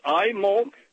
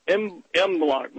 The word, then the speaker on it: Imbolc: Okay, yeah, that, I think that's very important because the halftime show is it's Imolk or it's Imblock. Well, let's spell it so people can look it up on the internet or in encyclopedia. It's Imbolc.